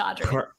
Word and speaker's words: Audriix: The X is silent, just Audrey.